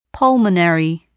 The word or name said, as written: pulmonary